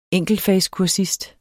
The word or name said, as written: enkeltfagskursist